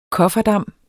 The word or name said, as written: kofferdam